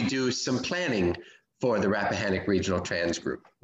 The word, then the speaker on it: Rappahannock: going to say it wrong. Rappahannock. – Correct.